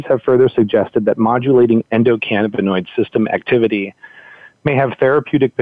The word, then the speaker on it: endocannabinoid: but it's the endocannabinoid system.